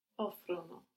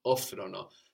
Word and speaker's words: offrono: Good, and we won't have the accent on the ono. – Offrono. – Offrono. So that's very important for our they form, no? We don't have the accent on our ending. Offrono.